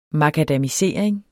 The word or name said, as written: makadamisering